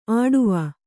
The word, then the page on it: ಆಡುವ: āḍuva